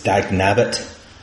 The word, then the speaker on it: Dagnabbit: Alan's saying that it's Dagnabbit. – Oh, there you go. So is that how you pronounce it? – Yeah.